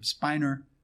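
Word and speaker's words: Spener: And so a few years later, this is in the 1600s, there's this Lutheran pastor named Jacob, I call him Jacob Spinner. Dr. Carter pronounces it Jacob Spiner because he actually knows how to speak German.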